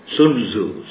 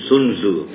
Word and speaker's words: Sun Tzu: The article I wrote, I spoke by God's mercy on Sun Tzu. But actually then I realized my pastor in Christ, he pronounced it, a Chinese pastor, he pronounced it Sun Tzu. So I better start pronouncing Sun Tzu.